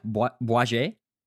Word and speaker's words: Bouygues: And I don't know how to say this other company. Bouygues? – Yeah, Bouygues. – Bouygues, merci.